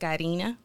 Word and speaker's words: Karina: My name is pronounced Karina.